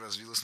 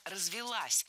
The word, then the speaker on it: развилась: Развилась, неверно, правильно, развелась. Ударение ставим на окончание.